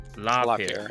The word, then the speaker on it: LaPierre: LaPierre.